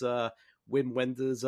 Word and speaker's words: Wim Wenders: and that's Wim Wenders, and I'm probably going to butcher the name, so please forgive me. – You got it right.